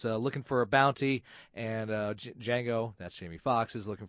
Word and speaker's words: Django: Django, Django, Django. – D is silent. Django.